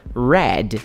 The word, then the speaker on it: read: A cor vermelha, todo mundo sabe que é red, R-E-D. E o verbo ler em inglês é to read. Quando você joga esse verbo pro passado, ele se pronuncia read, só que a escrita fica R-E-A-D. Então eles têm o mesmo som, olha que legal.